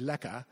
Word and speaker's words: lekker: And another word which we would pronounce as lekker, but South Africans, I think, would say lekka.